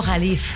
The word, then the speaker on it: Jalife: Entonces yo le dije, entonces explíqueme quién es Alfredo Califa. – Alfredo Jalif. – Ah, Jalif, eso. – Se escribe Jalife. – Jalife. – Jalif. – Sí, es Alfredo Jalif Ramé.